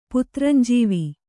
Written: ಪುತ್ರಂಜೀವ

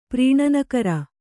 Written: ಪ್ರೀಣನಕರ

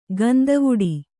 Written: ಗಂದವುಡಿ

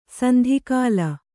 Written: ಸೆಂದುಕೋಲ್

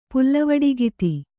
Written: ಪುಲ್ಲವಡಿಗಿತಿ

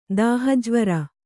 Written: ದಾಹಜ್ವರ

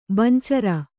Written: ಬಂಚರ

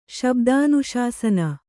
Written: ಶಬ್ದಾನುಶಾಸನ

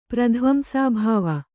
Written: ಪ್ರಧ್ವಂಸಾಭಾವ

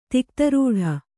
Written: ತಿಕ್ತರೂಢ

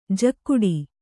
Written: ಝಾಗಟಿ

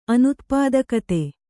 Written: ಅನುತ್ಪಾದಕತೆ

